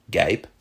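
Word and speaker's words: guêpe: Today, those words are pronounced Gaip and Gair.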